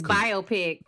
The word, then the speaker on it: biopic: Is it pronounced biopic or biopic? – It's biopic. – There we go. Just wanted to make sure.